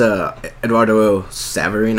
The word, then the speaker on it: Eduardo Saverin: Yeah, it's and also Andrew Garfield as Eduardo Saverin.